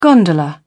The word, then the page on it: gondola: British English